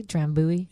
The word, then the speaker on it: Drambuie: Am I saying that correctly? Drambuie. – You are.